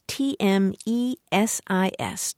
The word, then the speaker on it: tmesis: The other is tmesis. That's T M E S I S, tmesis.